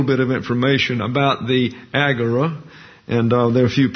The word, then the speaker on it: agora: And so the viticulturalist and the unemployed scabs meet in the agora. That's the way it's pronounced in English. It's agora in the Greek. Ente agora is the Greek phrase there.